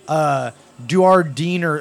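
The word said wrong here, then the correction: Dujardin: Jean, how would you say that in French? – Jean, right? – Jean Duardin.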